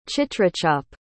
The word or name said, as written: chitrachap